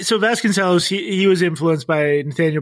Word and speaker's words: Vasconcellos: Yeah, I think it's pronounced Vasconcellos, but I'm legendarily bad at pronunciation. So well, I'm just... – Okay. As a musician, I'm going to say cellos. So Vasconcellos, Vasconcellos.